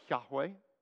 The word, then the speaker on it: Yahweh: In Hebrew, it would probably be pronounced more like Yahweh. In an Anglicanized version, we would say Jehovah.